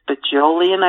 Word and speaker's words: Bohjalian: Uh, one of my favorite books of all time is called Midwives by Chris Bohjalian, I believe is the pronunciation of the name, enunciation of the name.